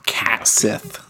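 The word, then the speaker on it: Cait Sith: Or Kate Sith. I think that's how to pronounce it. Kate Sith. That's how I've always said, right? – Yeah. Cat Sith.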